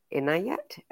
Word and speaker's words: Inayat: Inayat, have I said that right? – Yes, perfect.